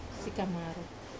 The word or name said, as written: kamaru